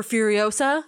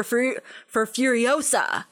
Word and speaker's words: Furiosa: Is it this minute where he does the line, "One bullet for Furiosa"? – Yes. – Yes, but he pronounces it awesome. He says "One bullet for Furiosa" instead of Furiosa. – Wait, Furiosa. Does anybody in this movie emphasize the "sa"? – Come on, it's a Harry Potter reference.